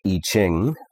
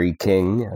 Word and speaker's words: I Ching: The Toltec I Ching, or I King, as some people pronounce it, came into my possession.